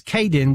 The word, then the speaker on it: Kaidn: It's Kaydin McDonald, and it is Kaydin, which is spelled K-A-I-D-N dot McDonald, 120 at mod.gov.